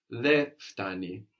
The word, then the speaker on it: δε φτάνει: Actually, in standard Greek, you will hear that N being dropped. Δε φτάνει.